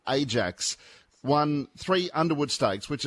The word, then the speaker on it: Ajax: There was the Dutch football team, mind you, pronounced Ajax,